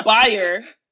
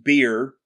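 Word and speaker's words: bier: What is a buyer? What is that? – It's a pyre that hasn't been lit yet. – No, it's not. It's actually pronounced beer.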